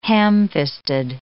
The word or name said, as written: ham-fisted